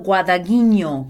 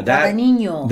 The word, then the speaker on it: Guadagnino: Yeah, I took Italian for 4 years in college, and it's Luca Guadagnino. – Yeah, Guadagnino.